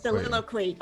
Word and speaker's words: soliloquy: I don't know how to pronounce it. – Soliloquy. – Okay, thank you.